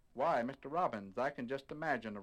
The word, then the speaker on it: Robbins: His name is Robbins, R-O-B-B-I-N-S, and it's pronounced just like the bird, Robbins.